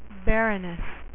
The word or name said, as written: baroness